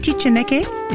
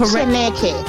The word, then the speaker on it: Tichaneke: It is pronounced Tichaneke. Is that correct? – Tichaneke.